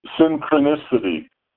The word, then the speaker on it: synchronicity: What you're saying is there's synocrisis. I'm mispronouncing it. Synocrisis. – Synopsis? This is a synopsis of what synchronicity.